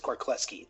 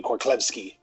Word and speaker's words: Korlewski: My last name is Korkleski. The W Kork- is silent. It's Polish. Okay. Yeah. Korkleski. – Yes. Okay. That's the Americanized version. If you want to go with the Polish version, it would be Korlewski.